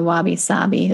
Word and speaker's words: wabi-sabi: And to approach life, like you mentioned, the wabi-sabi, am I saying it right? – Yes. Yes. That's correct.